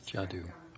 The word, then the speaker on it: Jaddou: Is it Jaddou? – Jaddou. I think so.